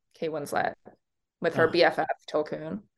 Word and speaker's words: Tulkun: I want to see the Tulkun, or how do you say it? – Tulkun.